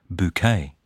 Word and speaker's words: Bucket: She pronounces it Bouquet.